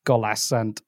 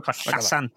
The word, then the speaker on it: collasant: No, you were pronouncing the double L as an L. So trosryddydd golasant. Golasant. Oh, I missed the T as well. Golasant. I gwaed. – Dros ryddid collasant ei gwaed.